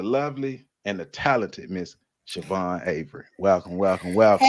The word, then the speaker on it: Siobhan: It's pronounced Siobhan.